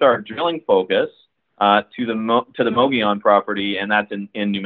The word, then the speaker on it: Mogollon: Well, let's transition over to the other project the company has over in New Mexico, and that's the high-grade silver-gold Mogollon property. I hope I'm pronouncing that right. – Yeah, that's a tough one to pronounce. It's Mogollon